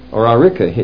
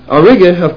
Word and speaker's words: Auriga: The other one is Auriga, Aurica, it shows it here. Let me see if I'm pronouncing that right. No, I have it Auriga here. I'll just check it for you in a hurry just to make sure we get it right. It's got a G, so this chart has it wrong.